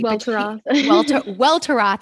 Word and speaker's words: Welteroth: And I saw that Elaine Welteroth, I always like pronounce her last name incorrectly. – Welteroth. – I always add in an N and I'm like, that's not right.